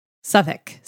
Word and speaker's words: Southwark: Southwark is pronounced how? – Southwark. I mean, I'm sure someone from there is going to critique my pronunciation, but it's close to that.